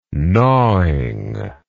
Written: gnawingly

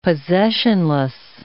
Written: possessionless

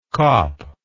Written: kop

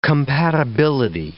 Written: compatibility

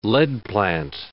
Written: leadplant

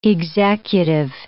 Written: executive

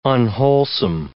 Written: unwholesome

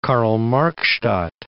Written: Karl-Marx-Stadt

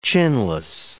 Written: chinless

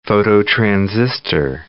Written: phototransistor

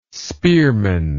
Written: spearman

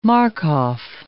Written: Markoff

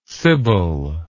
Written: sybil

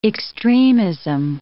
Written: extremism

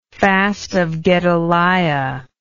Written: Fast of Gedaliah

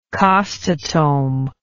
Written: costotome

